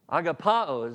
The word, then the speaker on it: agapao: Isn't it interesting that the word, the verb, this is the verb, agapao is the way you pronounce it, but this is the verb meaning to love this way.